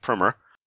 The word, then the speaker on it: Primer: And I should say that this week's movie is from 2004, Primer, or as some pronounce it, Primer.